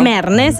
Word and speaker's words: Mernes: Pa, ¿cómo se dice? Mernes, ¿no? – Mernes, exacto.